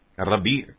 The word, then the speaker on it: ربیع: دیکھیے، اس کا تلفظ ہوگا ربیع نہیں ہوگا بلکہ رے بے یہ اور عین، ربیع